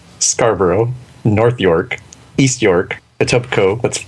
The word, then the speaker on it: Etobicoke: It's pronounced Etobicoke.